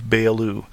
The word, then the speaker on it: Beyoğlu: And north of the Golden Horn is immediately the area of Beyoğlu. Don't be confused by the G that's in there that has an accent which is not pronounced.